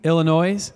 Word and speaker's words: Illinois: Today, we are celebrating the planting of Joy Church, Wheaton, Illinois. Is it the S or do you not say the S? Illinois, silent S.